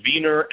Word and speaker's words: Wiener: And they're pronounced Wiener and Weiner in German.